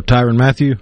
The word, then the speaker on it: Tyrann Mathieu: And then you got, okay, so this guy, I hope I say his name right, Tyrann Mathieu? Is that how you say his name? The player? – Oh, Tyrann Mathieu? – Mathieu. Okay, I wasn't sure.